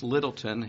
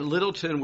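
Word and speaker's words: Lyttelton: One was a man named Lord George, and I believe his name is pronounced Littleton. It's got a weird spelling to it.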